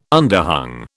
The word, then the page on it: underhung: British English